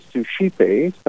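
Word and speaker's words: Suscipe: First thing people usually ask is, how do you say that? And it's called Sushipe, which is actually the name of a prayer that many people have probably heard by St. Ignatius that starts, take, Lord, receive.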